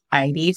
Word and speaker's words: Eide: So my name is Emmy and my last name is pronounced Eide for those who have a hard time. It's the most complicated four letters in the English language. So Emmy Eide, and I'm a director at Red Hat.